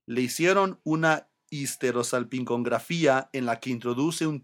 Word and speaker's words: histerosalpingografía: The Spanish word that more closely resembles histerosalpingography. Histerosalpingografía. La histerosalpingografía.